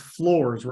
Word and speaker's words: Flores: It's Flores, right? – That's what I've been told. Now, granted, if we ever meet this kid, maybe he'll say it's Flores and we've been saying it wrong, but I was told it's Flores.